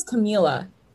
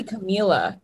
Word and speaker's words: Camila: I feel like we're saying her name wrong. – Did I say, what did I say? – It was Camilla. – I think it's Camilla Cabello. – No, I think it might be Camila.